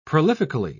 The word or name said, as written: prolifically